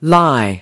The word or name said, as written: lie